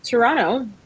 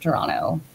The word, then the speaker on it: Toronto: And I too am based in Toronto. The T is silent, and I love raccoons.